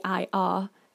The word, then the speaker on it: ir: So we need the verb to go, which is ir, which is literally IR, but it's pronounced ir.